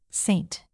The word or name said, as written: saint